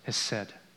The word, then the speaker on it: chesed: The Hebrew word is hesed. It's spelled C-H-E-S-E-D, but it's pronounced hesed. Here are a few instances where this hesed is used.